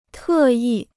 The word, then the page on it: 特意: tè yì